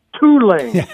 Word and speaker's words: Tulane: He said, "Well, first of all, it's pronounced Tulane," and conversation went on from there.